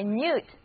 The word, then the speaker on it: minute: 但是如果我这样来念 minute, minute，哎，这个时候不但重音移到了第二个音节，而且字母 I 和 U 都不再读短的 I，而是分别读作它们各自的本音 I 和 U，于是这个单词的意思也就变了。 minute 表示细微的、详细的，微小的差别就是 minute difference, minute difference。